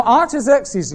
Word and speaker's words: Artaxerxes: A-R-T-A-X-E-R-X-E-S, Artaxerxes Longimanus, L-O-N-G, I-M-A-N-U-S.